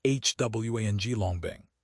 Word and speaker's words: Huanglongbing: Florida's citrus industry is basically gone, thanks to a disease called Huanglongbing, pronounced Huanglongbing.